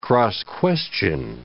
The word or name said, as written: cross-question